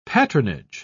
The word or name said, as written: patronage